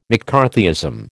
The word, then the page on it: McCarthyism: American English